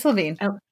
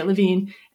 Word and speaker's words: Levine: I don't know how to pronounce his last name, to be honest. – It's Levine. – Okay, Levine. And he's done a couple other movies, but I haven't seen them.